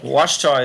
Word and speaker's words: Ouachita: Also, it's not spelled Wash. Like W-A-S-H. – No, it's spelled O-A-C-H-I-T-A. – O-U-A-C-H-I-T-A. – Wichita is a Baptist school, a Christian Baptist school.